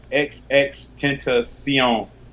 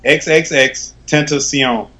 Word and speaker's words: XXXTentacion: XXXTentacion. – XXX? – XXXTentacion.